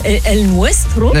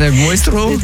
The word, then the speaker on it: El nuestro: El nuestro. – El nuestro. – T'as vu, je le dis bien.